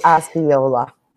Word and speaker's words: Osceola: It would be Osceola.